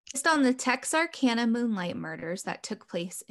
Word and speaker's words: Texarkana: Texarkana. – Texarkana.